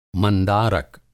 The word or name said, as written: मंदारक